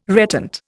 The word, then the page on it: reptant: Indian